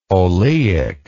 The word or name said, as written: oleic